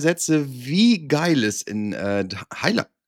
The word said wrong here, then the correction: Thailand: Ich kann das auch mal normal aussprechen. In Thailand ist.